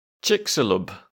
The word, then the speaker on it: Chicxulub: The pronunciation, according to the internet, is Chicxulub.